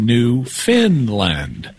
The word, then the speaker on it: Newfoundland: If the call screener got it right, it's spelled Newfoundland, but it's pronounced Newfinland, even though it's not located in Scandinavia.